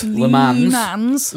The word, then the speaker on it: Le Mans: Do I say it like Lee says it, or says it properly? – Le Mans.